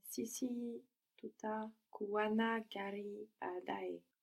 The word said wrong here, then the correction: kuwa na: Good, but you pronounce kuwana like it's one word, so I just want to clarify that it's two. Kuwa na, and then you have like kind of two different stresses.